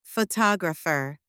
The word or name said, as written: photographer